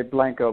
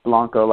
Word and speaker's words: Blanco: Some people say Blanco, but it's pronounced Blanco, like the last name.